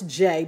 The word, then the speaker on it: Jai: So my name, I know it's Jay, but it's spelled J-A-I.